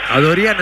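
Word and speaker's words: Adoryan: Yo dije a Dorian y me parece que se pronuncia así. – Es porque es de ascendencia armenia, el amigo. – Bueno, a Dorian entonces será.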